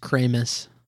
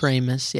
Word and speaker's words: Kramus: I think it's pronounced Kramus. – Kramus, Kramus. – Kramus, yeah, like Kramer. Yeah, Brock is just like a powerhouse.